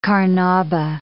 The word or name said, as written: carnauba